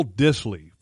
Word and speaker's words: Dissly: The guy that I do like is Will Dissly from Washington. – Will Dissly. – I think that's how you pronounce his name. It's D-I-S-S-L-Y.